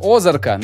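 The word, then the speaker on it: Ozark: значит, смотри, когда я посмотрел первый сезон Озарка, будем называть на первый, на первый слог ударение будем.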